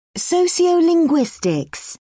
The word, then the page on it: sociolinguistics: British English